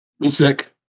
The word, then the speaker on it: Usyk: Usyk.